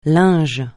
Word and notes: linge: francais

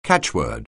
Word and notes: catchword: anglais

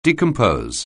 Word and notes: decompose: anglais

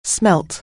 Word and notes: smell: anglais

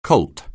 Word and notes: Colt®: anglais

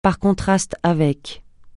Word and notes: par contraste avec: francais

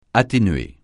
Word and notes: atténuer: francais

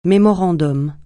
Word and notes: mémorandum: francais

